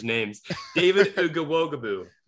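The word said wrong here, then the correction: Ugwoegbu: That's not even close. David Agwegbu.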